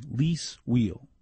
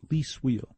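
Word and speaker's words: Lis Wiehl: Name pronunciation, Lise Wheel. That's how you pronounce it, Lise Wheel.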